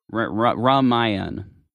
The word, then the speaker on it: Ramayan: Ramayan, Rami Malek. – Rummy Yummies? No. – Rami on Hulu. – So a fun way to pronounce